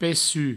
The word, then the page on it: pecuc: pessu; provencal